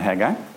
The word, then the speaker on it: Haggai: After 6 weeks, hopefully we've cut the time to find Haggai down from 10 minutes to 5 minutes. That would be a good thing. So how we pronounce the word, it's actually Haggai, it's not Haggai.